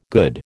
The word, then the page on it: good: American English